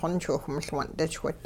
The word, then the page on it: xonchwiwh-miL-wun'dichwit: Hupa